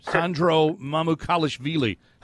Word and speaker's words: Sandro Mamukelashvili: And the other guy I got to mention is, just because I want to say his name, Sandro Mamukelashvili.